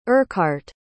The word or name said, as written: urquhart